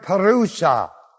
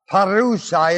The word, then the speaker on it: parousia: Christ's return for His church class is a Greek word that's spelled, it's a Greek word parousa. Parousa is the word. And it is spelled P-A-R-O-U-S-I-A. That's the word. Parousa. P-A-R-O-U-S-I-A. Parousa.